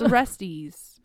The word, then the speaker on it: Orestes: I really hope someone comes at you and is like, that's not how you pronounce Orestes. – It's Orestes.